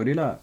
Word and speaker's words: Rila: Ryla? Rilla? I don't know how to say it. R-I-L-A. – Oh, Rilla.